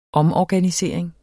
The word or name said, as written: omorganisering